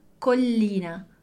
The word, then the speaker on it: Collina: Vamos pronunciar primeiro um L e depois o outro. Collina. Collina. Então, a gente tem a sílaba col, e depois a sílaba li. Então, a gente tem esse primeiro L, pronuncia ele sozinho, e depois pronuncia o outro também.